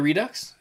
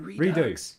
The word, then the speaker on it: Redux: Oh, I've been pronouncing it Redux. – Redo. – I think Redux sounds cool. – It's Redux? – I'm American.